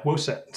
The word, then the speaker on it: WOSET: And do you want to plug, is it Woset? How do you pronounce it? – Oh yeah, Woset.